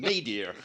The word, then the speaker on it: nadir: That was the nadir, as they say in America, I believe. That's the pronunciation, nadir.